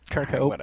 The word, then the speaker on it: Kirkhope: God damn it, that's not how you say his name, is it? – I doubt it. – All right. How do you say his name? – I'd pronounce it Kirkhoff, but... Yeah. – It seems pretty phonetic, Andre. Kirkhope.